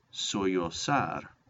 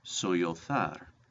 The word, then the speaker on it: sollozar: Number nine, sollozar, or in Spain it would be pronounced sollozar. This means to sob and weep. Sollozar or sollozar. Remember, there's no Y in that word.